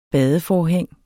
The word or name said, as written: badeforhæng